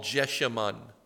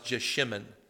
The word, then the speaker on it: Jeshimon: It is called Jeshimon, and sometimes pronounced Jeshimon.